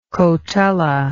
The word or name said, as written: cotula